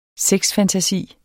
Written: sexfantasi

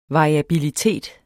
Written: variabilitet

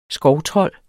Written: skovtrold

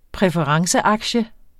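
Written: præferenceaktie

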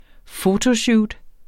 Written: fotoshoot

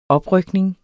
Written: oprykning

